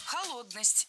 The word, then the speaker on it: холодность: Холодность. Неверно. Правильно, холодность. Ударение в этом слове такое же, что и в исходном существительном. Холод, холодность. Легко запомнить.